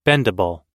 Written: bendable

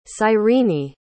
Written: cyrene

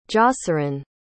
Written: joceran

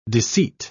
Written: deceit

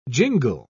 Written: jingle

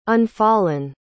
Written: unfallen

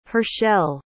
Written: Hershell

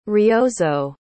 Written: ryozo

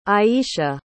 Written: ayesha

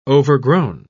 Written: overgrown

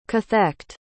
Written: cathect

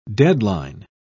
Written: deadline

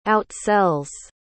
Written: outsells

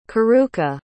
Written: karuka

